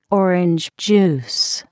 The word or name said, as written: orange juice